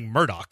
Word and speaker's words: Murdaugh: But it's pronounced like Murdaugh.